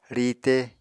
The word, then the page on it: riite: Apatani